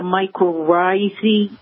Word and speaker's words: mycorrhizae: the mycorrhizae? – Yes.